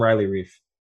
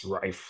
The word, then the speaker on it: Reiff: In Germany, there's not a lot of R-E-I sounding names. – Okay. – But I always called him Riley Reef, but I just heard that if it's German, it's pronounced Reif.